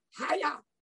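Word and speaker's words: hiya (חָיָה): "Hiya!" That is precisely how you pronounce the Hebrew word for revived.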